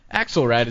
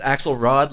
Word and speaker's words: Axelrad: Is that the correct way of pronouncing it? – That's the first time it has ever been pronounced correctly on the air. – Oh, wow. I appreciate that. – Everybody says Axelrod, like David Axelrod, and I've gotten very used to that, so I appreciate your nailing it.